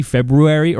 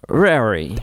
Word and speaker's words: February: I know it's pronounced technically February or whatever that is. – Yeah, there's an R in there. – February.